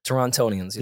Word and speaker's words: Torontonians: Torontonians.